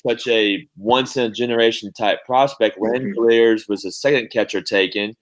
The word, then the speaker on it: Langeliers: His last name is Langeliers. Is that pronounced his last name? – Langeliers.